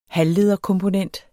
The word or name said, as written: halvlederkomponent